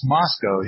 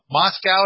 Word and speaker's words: Moscow: And I kind of, and then I remember there, they're like, no, if you're talking about the one in Russia, that's Moscow. – But this, it's spelled the same, okay. But it's Moscow here in Idaho.